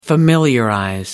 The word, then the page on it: familiarize: British English